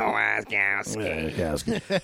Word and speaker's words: Wasikowska: Mia Wasikowska? I have no idea. – Wasikowska. – I don't know. – Mia Wasikowska.